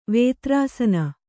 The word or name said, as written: ವೇತ್ರಾಸನ